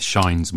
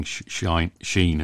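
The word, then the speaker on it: Schein: That happened to me last week with Shine's model. An Indian friend was saying Shine, and I was just not registering Sheen, as I would say.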